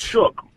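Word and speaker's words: Shough: how do you say his last name? – Is it Slouch? Slouch? – Shook. – Shook.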